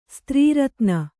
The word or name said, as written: ಸ್ತ್ರೀರತ್ನ